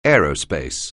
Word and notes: aerospace: anglais

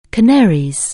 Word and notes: Canaries: anglais

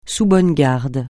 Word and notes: sous bonne garde: francais

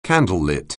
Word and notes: candlelit: anglais

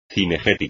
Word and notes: cinegética: espagnol

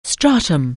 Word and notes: stratum: anglais

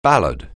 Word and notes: ballad: anglais

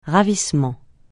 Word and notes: ravissement: francais

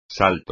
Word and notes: salto: espagnol

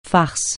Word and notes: farce: francais

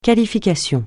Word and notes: qualification: francais

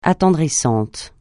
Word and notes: attendrissant: francais

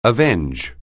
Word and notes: avenge: anglais

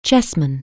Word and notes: chessman: English

